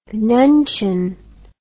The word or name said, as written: nuncheon